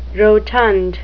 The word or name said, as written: rotund